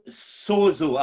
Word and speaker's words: sozo: And that word is sozo. I want you to take note of it. It's spelled S-O-Z-O.